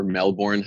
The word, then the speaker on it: Melbourne: As soon as I stepped on that plane from LA to Melbourne or Melbourne, how us North Americans might pronounce it,